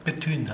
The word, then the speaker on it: Bethune: wie wird er ausgesprochen? Bethune hat er sich Bethune, ah ja.